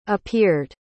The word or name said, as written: appeared